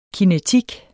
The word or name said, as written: kinetik